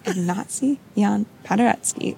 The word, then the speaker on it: Ignacy Jan Paderewski: In 1893, I believe, and a few times before and after that, the pianist, and forgive me if I'm saying it wrong, is it Jan Paderewski? – Ignacy Jan Paderewski. – Okay, I butchered it.